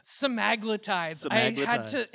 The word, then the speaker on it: semaglutides: Semaglutides? – Semaglutides. It took so long for me to figure out how to say that right.